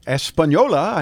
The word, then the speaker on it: Española: Española, I know how to say that.